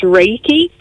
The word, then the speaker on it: reiki: It's grand, and I know recce has this wonderful ability to take all the stress off you, hasn't it? What is it? – It does. It does indeed. So it's actually pronounced reiki, and it is a non-invasive hands-on therapy.